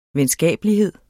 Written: venskabelighed

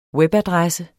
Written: webadresse

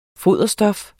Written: foderstof

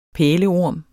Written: pæleorm

